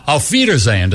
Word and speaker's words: Auf Wiedersehen: The reason technically it should be BMW is because in German, the letter W is pronounced like a V. Like you say, Auf Wiedersehen, to say goodbye. If you spell out Wiedersehen, it begins with a W.